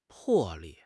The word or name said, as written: 魄力